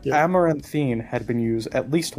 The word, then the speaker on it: Amaranthine: We are going to move on to SCP-7070 Amanthrine? Do you know how to pronounce this, Plague? – Amaranthine.